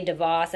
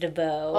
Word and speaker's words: DeVos: Remember, I couldn't even say DeVos. I thought it was DeVoe.